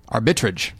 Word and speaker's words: Arbitrage: You know, whereas you get like a movie like Arbitrage, which I still don't know if I'm saying it right. – Yeah. Arbitrage. That's how it is.